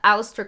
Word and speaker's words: Aleister: Aleister Crowley. – Aleister. Is that his name? Aleister? – And it's spelled weird. It's spelled A-L-E-I-S-T-E-R.